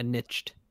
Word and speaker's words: niche: Like, the word is pronounced niche. – What did I say? – Nice. – Niche.